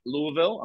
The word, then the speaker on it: Louisville: If anyone's around, I'm saying it right, Louisville.